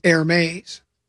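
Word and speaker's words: Hermès: So my, you know, the Grum pretty much has an expert for anything, and my handbag expert says it's pronounced Hermes.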